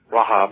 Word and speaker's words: Rahab: Her name is Rahab, or Rahab is the Jewish pronunciation.